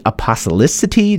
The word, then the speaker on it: apostolicity: The other three we'll do in part two will be apostolicity. Did I say that correctly? – I think you did.